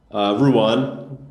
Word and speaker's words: Ruan: Ruan. I mean, with your accent, it actually sounds fine.